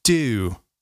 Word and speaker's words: D.E.W.: We're trying the Tullamore Dew. But I've pronounced it in the intro D-E-W because they're actually initials, the D-E-W.